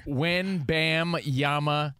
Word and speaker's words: Wembanyama: Wembanyama. That's easy. – Chachki. – Dan Patrick calls him Wembanyama. – There's an N in there. – When, bam, yama, it's spelled exactly how it sounds.